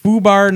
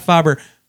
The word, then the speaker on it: FUBAR: Fubar, not Fobber. You know what, Fubar? Then you need to spell it right, because it's spelled F-U-B-B-A-R. It's F-U-B-A-R.